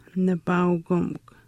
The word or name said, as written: nipaaukimikw